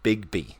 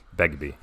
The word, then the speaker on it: Begbie: Yeah, Robert Carlyle as Bigby. Begbie.